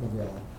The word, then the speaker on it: McGrath: It looks like McGrath, but he pronounces it McGraw, at a seminar conducted in April 2003.